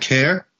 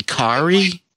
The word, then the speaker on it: Kaare: Carrie Andrews is spelled K-A-A-R-E. So, is that Care? – Is it? – I think it might be Kari.